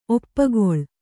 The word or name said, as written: ಒಪ್ಪುಗೊಳು